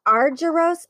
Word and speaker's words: Argyros: Ned is the CIO for a multi-billion dollar family office, the Argyros family.